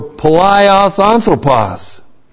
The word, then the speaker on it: palaios anthropos: That palaios, P-A-L-A-I-O-S, and then anthropos. A-N-T-H-R, long O-P-O-S. Palaios anthropos.